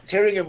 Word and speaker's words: Tyringham: And also you talked a bit about your initiative, I don't know how to say it correctly, the Triringum. Yeah. So what's that all about, man? – Triringum, Tyringham Hall